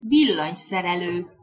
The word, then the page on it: villanyszerelo: Hungarian